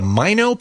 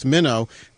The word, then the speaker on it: Mino: Speaking of smaller and lighter, tell me about is it the Mino pocket camcorder? – It's the Minnow, M-I-N-O, but pronounced Minnow.